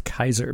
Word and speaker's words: Caesar: Now I remember, and I just double checked this on Reddit's Ask Historians, but the Latin Caesar that we always say, you know, Caesar salad, is actually pronounced Kaiser because the Latin uses hard K sounds for their C's.